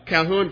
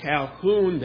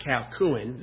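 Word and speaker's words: Colquhoun: By the way, you see that I quote Calhoun there. I don't know if it's Calhoun or Calhoun. It's not Calcuin. The Q is silent. I didn't know that for a long time.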